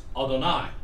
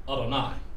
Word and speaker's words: Adonai: And it's pronounced Adonai. Adonai. You notice here the difference between the, in the A, one is an I and the other is an uh.